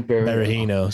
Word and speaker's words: Berahino: I look at Patterson, Birano, what's his name? – Berahino, Saido Berahino.